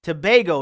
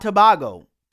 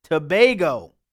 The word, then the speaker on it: Tobago: Tobago, not Tobago. Tobago. Sorry about that. Thank you for the correction.